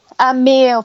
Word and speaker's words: Emil: Emil, I'm never sure how to pronounce it. Emil, thank you.